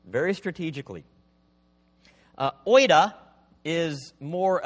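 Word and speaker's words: oida (οἶδα): They're pronounced oida and gnosko.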